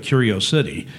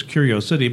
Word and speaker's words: curiosity: And, and uh, there was a time when people would call that Curio City, and it's only because the way it's spelled. It's spelled C U R I O C I T Y. So it's Curiosity, but it's pronounced curiosity.